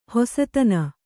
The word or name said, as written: ಹಸುತನ